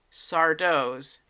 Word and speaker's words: Sardo: we get to go inside of Sardo's Magic Mansion. That's right, they brought back Sardo. No Mister, accent on the Doe's Magic Mansion.